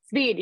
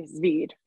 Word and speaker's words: Zveed: Zveed. Yep. You have a nice, you say the V nice. You say Zveed.